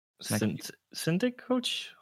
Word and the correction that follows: Synecdoche: How do you pronounce that? Something. – Synecdoche. – Is it Synecdoche, New York? – Yeah.